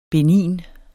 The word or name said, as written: benign